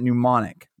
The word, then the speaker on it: Xmnemonikx: So you pronounce it Mnemonic, but to actually find him on Spotify, I'm going to spell it out because it's a little different.